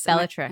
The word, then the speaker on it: Bellatrix: So this is from a chosen name, Bellatrix. – Bellatrix. – Am I saying it correctly? – Yeah.